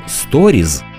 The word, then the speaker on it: сторіз: Щодо інстаграму і функції сторіс, то українською найкраще вживати або наш відповідник історії, або ж писати та вимовляти слово сторіз через з наприкінці.